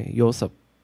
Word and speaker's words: Yosep: But in Korea, Yosep. – Yosep? – Yeah, more like Y sound, not like J sound. – How would you spell that in like Y-O-S-E-O-P, Yosep.